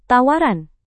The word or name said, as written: tawaran